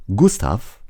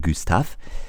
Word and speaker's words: Gustave: Er heißt Gustav oder französisch ausgesprochen Gustav.